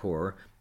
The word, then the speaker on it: coir: Now, coconut core, that, by the way, is spelled C-O-I-R, not C-O-R-E, is the material between the outer shell of a coconut and the inner shell.